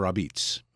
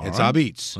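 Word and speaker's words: apizza: It's pizza with an A in front, which is the New Haven pronunciation. You don't say you're going out for pizza. You say, I'm going for Abitz. Abitz.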